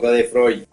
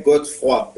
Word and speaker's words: Godefroy: Está perfecto, Godefroy. – Ok. – en francés sería Godefroy, pero bueno, aquí en México es Godefroy.